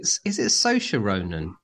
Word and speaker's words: Saoirse: is it Saoirse Ronan? – That's the one.